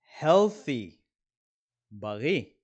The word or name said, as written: healthy